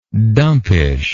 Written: dumpishness

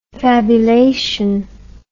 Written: fabulation

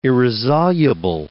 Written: irresoluble

